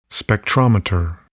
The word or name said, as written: spectrometer